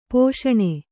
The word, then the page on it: ಪೋಷಣೆ: pōṣaṇe